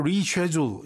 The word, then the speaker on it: reschedule: reschedule 발음을 reschedule 이렇게 발음도 하죠.